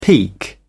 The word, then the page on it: peak: British English